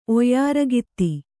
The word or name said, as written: ಒಯ್ಯಾರಗಾತಿ